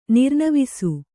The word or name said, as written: ನಿರ್ನವಿಸು